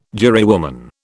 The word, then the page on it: jurywoman: British English